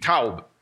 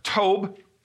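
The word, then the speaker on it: taub (טוב): but it's T O W B taub. It also can be pronounced tob T O B E.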